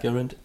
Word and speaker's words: Gurund: Gurund, have I said that right? – Yeah. – Gurund.